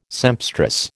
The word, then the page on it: sempstress: American English